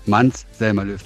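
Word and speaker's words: Måns Zelmerlöw: Ja, Mans Zelmerlöw.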